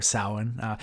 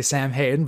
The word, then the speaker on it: Samhain: We do have a get together with all of our students for Samhain. By the way, you pronounce it correctly, so I'm impressed. I'm impressed. A lot of people say Sam Hayden, but not Samhain.